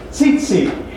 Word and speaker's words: tzitzit: And the tassels are called tzitzit. It's spelled T-Z-I-T-Z-I-T. Not zit, but tzitzit.